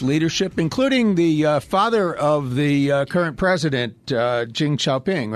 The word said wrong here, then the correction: Xi Jinping: Xi Jinping.